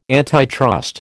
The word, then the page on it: antitrust: American English